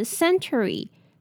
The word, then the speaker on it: century: 我们先来看发音，第一个字 century, century. 虽然它不是写 ch, 只有一个 t, 但是这里的 t 是发 ch 的音，所以是 century, century. 再来是 preserved, preserved. P-R-E 是念 pre 的音，那有其他类似的字，像是 prepare, predict, 或是 prefer。